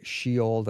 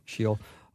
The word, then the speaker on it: Sheol: Hades, the grave, Sheol, did I say that right? – Yeah. – Sheol.